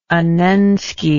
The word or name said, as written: annensky